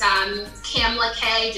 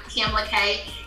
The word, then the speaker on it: Kamla Kay: Or if you go to my Instagram, it's Kamala K, just at Kamala K. And I know my name is like, how do you spell it? So K-A-M-L-A-K-A-Y, so Kamala K is on Instagram.